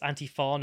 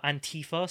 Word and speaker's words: Antifa: So this is her atting BBC World Service, saying it's Antifa, not Antifa. Stress on the latter syllable.